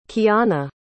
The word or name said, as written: keyanna